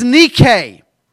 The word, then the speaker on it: Nike: In America, we say Nike. In England, we say Nike. The actual pronunciation of this word is Nike. But it's the same word, N-I-K-E.